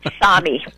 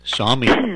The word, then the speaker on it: Sami: Sami. – Sami.